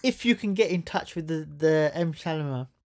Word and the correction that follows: Shyamalan: Shyamalan, yeah.